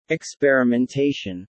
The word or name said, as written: experimentation